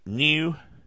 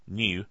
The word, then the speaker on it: GNU: You know, new, which is pronounced G, or spelled G-N-U, new, New stands for news, not Unix. Okay, N-U. I don't know why I keep wanting to say N. G-N-U. I guess because the G is silent. I'm making it silent even in my mind. There you go. G-N-U, new, is not Unix.